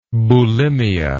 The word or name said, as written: boulimia